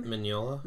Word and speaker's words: Mignola: Mignola.